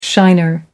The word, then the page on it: shiner: American English